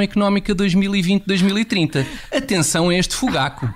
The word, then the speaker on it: Fugaku: Convém garantir que se pronuncia Fugaku, com o acento tónico na penúltima sílaba e não na última sílaba.